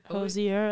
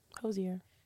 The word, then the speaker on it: Hozier: I will go straight for Mr. I never can say his name. Hozier. – That's how I pronounce it. – Hozier.